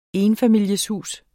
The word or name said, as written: ENFAMILIESHUS